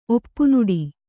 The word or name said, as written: ಒಪ್ಪುನುಡಿ